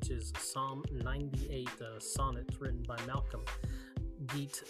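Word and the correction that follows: Guite: in response to that. Or Geit, I should say. I always want to pronounce his name the wrong way.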